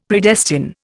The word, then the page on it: Predestine: Indian